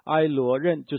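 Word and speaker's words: 埃 罗 任 (עליון): 第一个就是至高的神，至高的神，如果按原来的发音呢是埃罗任，就是我们就不解释它了。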